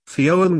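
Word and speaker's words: Fyn: The largest island between Jutland and Sjælland is named Finn, pronounced something like Fionn with the F and Y making a single sound.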